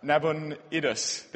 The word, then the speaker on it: Nabonidus: Eventually, someone called Nabonidus, sorry, I'm really tired, I'm trying to pronounce it, Nabonidus, there we go, takes the throne.